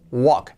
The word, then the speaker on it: walk: Es el verbo caminar, escrito walk, Cuidado que no es walk, es walk. La L no se pronuncia, walk, es muda, walk.